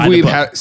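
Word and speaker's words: Wiebe: It was Steve Weeb.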